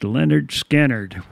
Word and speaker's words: Lynyrd Skynyrd: Before Charlie Daniels, we had Lynyrd Skynyrd, one of their classic songs, "Simple Man," from their debut album, pronounced Lynyrd Skynyrd, which was produced by Al Kooper and released in the summer of 1973.